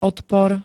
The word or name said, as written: odpor